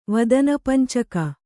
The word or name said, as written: ವದನಪಂಚಕ